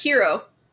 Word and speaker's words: gyro: Oh, gyros, though. – And what is a gyro? What is that? – It's a Greek. – Technically, it's pronounced hero.